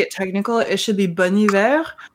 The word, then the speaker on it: Bon Iver: Please be aware that I didn't know any of this until looking it up, but Bon Iver. It's Bone. – That's my... Oh, I thought it was Bon Iver. That was always my understanding of the pronunciation.